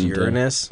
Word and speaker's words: Uranus: It's pronounced Uranus. – Uranus. That's true, right? That's actually how it's pronounced.